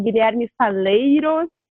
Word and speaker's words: Guilherme Falleiros: É, o nosso primeiro convidado é o Guilherme Faleiros. É assim que pronuncia, tá certo? – Sim, é um jeito bem normal e tranquilo de se pronunciar.